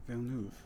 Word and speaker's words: Villeneuve: Villeneuve.